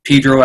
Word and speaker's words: Pedro: Pedro, pronounced Pedro as opposed to San Pedro, the way that you could tell the townies from the out-of-towners.